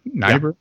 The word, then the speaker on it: Niebuhr: Richard, is it Niebuhr? – Niebuhr. Yeah. – Niebuhr. Okay. – H. So, Richard Niebuhr, and his brother Niebuhr. is the famous American theologian Reinhold Niebuhr that Obama yeah.